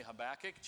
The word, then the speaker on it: Habakkuk: We've been here in the Minor Prophets for the last several weeks, and we've reached now this book of Habakkuk, or Habakkuk, as some say. I'm going to say Habakkuk, just so you know. That's how we'll pronounce it for this sermon.